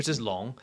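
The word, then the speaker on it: Long (龙): Yeah, and this is how you pronounce the Chinese for dragon, which is Long.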